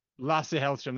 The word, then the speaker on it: Lasse Hallström: Lasse Hallström, that's the one.